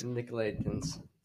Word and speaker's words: Nicolaitans: The Nicolaitans. – Nicolaitans. It's like Nickelodeon.